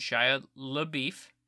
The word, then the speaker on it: Shia LaBeouf: It starred Bobby Soto and Shia LaBeouf, as I believe it's pronounced.